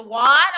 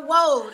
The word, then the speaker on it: Woad: But even now, people still have problems pronouncing it because they say, oh, it's the Woad. I'm like, no. It's the Woad. It's like Rose with a W. Just Woad.